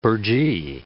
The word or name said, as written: burgee